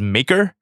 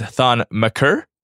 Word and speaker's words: Thon Maker: You pronounce Thon Maker as Maker. Again, it is Thon Maker.